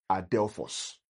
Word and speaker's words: Adelphos: Now the word there, brethren, is the word brother, Adelphos. Adelphos is spelled in the Greek, is A-D-E-L-P-H-O-S, Adelphos, right?